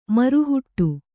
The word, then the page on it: ಮರುಹುಟ್ಟು: maru huṭṭu